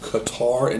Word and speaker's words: Qatar: Well, we got two Gold Cup games, and I wanted to say that yesterday I did smack a nice two-teamer with Qatar and Mexico.